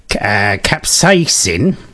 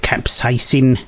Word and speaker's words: capsaicin: Capsaicin, I think that's how you pronounce it, capsaicin, C-A-P-S-A-I-C-I-N, which is from chili peppers, raises body temperature and energy expenditure.